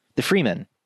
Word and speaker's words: Fremen: You know, you've got the way they kind of frame the indigenous people, I think the Femur, am I saying that right? The Fremen.